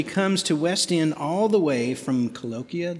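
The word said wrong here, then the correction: Culleoka: Did I say that right? – Colioka, Tennessee, which is near Columbia, where she is a student at Columbia State University.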